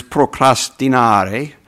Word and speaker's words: procrastinare: You know, most of our words come from Latin or Greek, and the Latin word is procrastinare, which I give an Italian pronunciation.